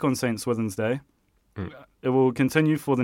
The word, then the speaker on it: St. Swithin's Day: St. Swithin's Day. – Oh, you're pronouncing it quite well. – Yeah. St. Swithin's Day.